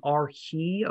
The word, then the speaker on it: Arche: In chapter 3 in verse 14, it says that Jesus is the Arche of creation. That's my modern Greek pronunciation there.